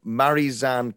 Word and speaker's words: Marizanne: now I never say her name correctly, but it's why. – well, no, Marizanne Kapp. Yes.